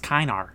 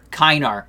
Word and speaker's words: Kinnear: I think it's pronounced Kinar. – Kinar. – Oh, shit, yeah. I thought it was like a French, like Kinnear. – That makes sense. – Oh, that's probably how he would pronounce it.